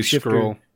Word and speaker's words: Skrull: director alongside Ben Mendelsohn, Skull, however you pronounce it. Supposed to be Skrull. You're missing, yeah, that's an, what I was like, you're missing an R.